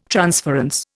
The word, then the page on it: transference: Indian